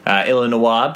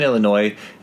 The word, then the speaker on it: Illinois: Illinois being the French pronunciation, Illinois being how we say it.